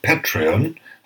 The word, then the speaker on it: Patreon: Das gilt insbesondere für unsere inzwischen über 100 Patrons auf Patreon. Habe ich das jetzt richtig ausgesprochen, Frank? Top.